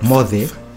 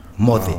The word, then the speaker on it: Moce: But it's not pronounced C. It's TH. – Ah. – Like Mothe. Mbae. – Yeah. – M-O-C-E. Mothe.